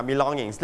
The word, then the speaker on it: belongings: The things or objects that you own, ครับก็คือสิ่งของที่คุณต้องเตรียมหรือสิ่งของที่คุณมีอยู่ใช้ในการเดินทางนะครับ we call it belongings, นะ belongings. Listen to the audio twice, the first time. – Belongings. – One more time. – Belongings. – Your turn, belongings. – Belongings. – Belongings. – Belongings. – So you stress on the first or second syllable? – Second. – Second syllable, long, long.